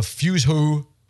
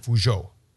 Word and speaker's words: Fuzhou: That sounds more French than it does Chinese. – Yes, that's the way it would be pronounced, I believe. – Fuzhou. Maybe with a little bit of oomph behind it. Maybe that sounds too much Japanese. Fuzhou.